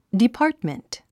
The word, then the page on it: department: dipɑ’ːrtmənt; ディパァトメント